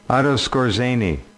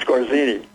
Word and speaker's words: Skorzeny: Otto Skorzeny, yeah. – Skorzeny, okay, I'm not pronouncing it right. – That's right. – Skorzeny. I didn't realize how it was pronounced.